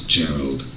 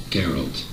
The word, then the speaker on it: Geralt: Not Geralt. Geralt. – ¿Qué habéis oído? No lo pronuncies mal, copón.